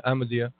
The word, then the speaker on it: Ahmadiyya: Yeah, Ahmadiyya.